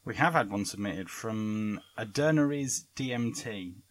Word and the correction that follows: Aidan Ares: Oh, fuck's sake, I've mispronounced his name. So, we've come to that point in the show where we have Argue the Inarguable. Aidan Ares, DMT.